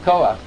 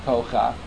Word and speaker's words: Koach: The correct pronunciation, like Koach, is not pronounced when you have a word that ends with a Ches, with a Padot, so it's not pronounced Kocha, it's pronounced Koach.